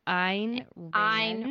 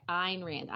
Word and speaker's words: Ayn Rand: I thought it was Ayn Rand. – Ayn Rand. – That's the story I heard a long time ago. – Yes, you're right. You're right. Ayn Rand.